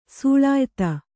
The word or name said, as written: ಸೂಳಾಯಿತ್ತ